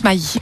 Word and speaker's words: Maddi: ça s'écrit M-A-D-D-I, mais on le prononce Maï.